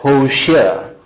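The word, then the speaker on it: Hoshea (הושע): The name Hosea in Hebrew is pronounced as Hoshea.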